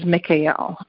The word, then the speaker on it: Mikael: and the pronunciation we're going to be using and working with tonight is and that I use in this chant is Mikael.